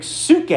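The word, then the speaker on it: psyche: It's pronounced in Greek suke, and do you know what that word is interpreted in your? It's in your Bibles. Suke means soul.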